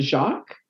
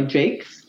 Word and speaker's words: Jacques: So when Pat Jakes, it looks like her last name, you would probably be inclined to pronounce it Jacques. It's J-A-C-Q-U-E-S. – Right. – But they're in the States, so she goes by Jakes.